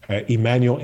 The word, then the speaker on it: Emmanuel: And it's Emmanuel NMA, all one word.